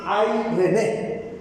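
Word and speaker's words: Airene (εἰρήνη): Se pronunciaría así: Airene. Diga conmigo: Airene. Como que si usted conociera a alguien que se llama René y le dijera: ay, René. Entonces, algo así: ay, René. Dígalo conmigo: ay, René.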